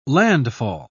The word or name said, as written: landfall